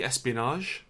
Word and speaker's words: espionage: Is that a soft G? Espionage? – Espionage. – It's not espionage, is it? There's no D.